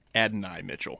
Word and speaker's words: Adonai Mitchell: The seventh wide receiver on the football guys ranking which is maybe what the Bills will have to settle for, is, am I saying it right? Adonai Mitchell? – Adonai Mitchell.